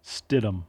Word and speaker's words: Stidham: Jarrett Stidham.